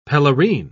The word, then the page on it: pelerine: British English